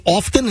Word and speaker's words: often: You say, you pronounce the T in often? – I do.